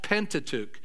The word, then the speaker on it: Pentateuch: It's pronounced Pentateuch.